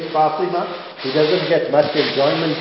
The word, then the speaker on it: Fatima: I must pronounce it properly, Fatima.